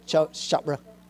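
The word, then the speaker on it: Chironna: Now, Dr. Mark, now I hope, let me help pronounce this right, because his name is spelled C-H-I-R-O-N-N-O. Sharona? Sharona. Sharona, okay.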